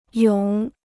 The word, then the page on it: 涌: yǒng